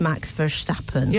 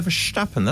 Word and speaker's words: Verstappen: I feel like we've mentioned this before, but I have looked online at how to pronounce it correctly, Dutch way. – Yeah. – And this says Max Verstappen. – Yeah, Verstappen.